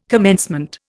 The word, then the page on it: commencement: Indian